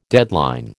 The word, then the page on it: deadline: American English